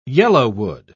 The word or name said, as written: yellowwood